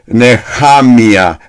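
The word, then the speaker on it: Nehamia (נחמיה): Pero Nehemías, como se le llama, su nombre, pronunciación de Nehemías es Nehamia.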